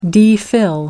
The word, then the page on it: DPhil: American English